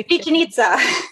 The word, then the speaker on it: Chichen Itza: Pyramid of, I want to say Chichen Itza.